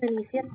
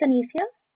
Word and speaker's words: Anicia: This is Anicia speaking. How may I help you today? – Hi, this is Jody from XPO, and I'm sorry, I didn't catch your name. – It's Anicia. – Okay, you spell that for me? – A-N-I-C-I-A.